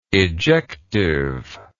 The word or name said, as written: ejectively